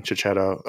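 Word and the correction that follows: Checchetto: Oh, Kaketo. – Oh, yeah. – It was Kaketo. The first opportunity I had to say it right. I did it wrong.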